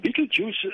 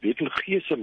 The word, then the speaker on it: Betelgeuse: Ja, Betelgeuse is eintlik maar die regte uitspraak daarvan. Party mense sê dit Betelgeuse, maar dit is een van die 10 helderste sterre wat in ons hemelruim snags sigbaar is.